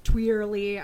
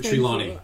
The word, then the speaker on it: Trelawney: It's like Twirly. I'm so bad at it. – Trelawney.